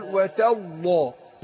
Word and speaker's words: وتالله: أنا أقول وتالله وتالله اللام هنا مفخمة. أقول أرقق التاء هي أقول وتالله.